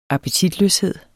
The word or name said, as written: appetitløshed